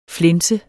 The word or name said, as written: flænser